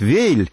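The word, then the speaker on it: Weill: Кстати, заметьте, что я говорю Валь, хотя традиционное российское произношение предлагает Вейль.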